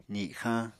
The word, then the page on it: zixañ: apatani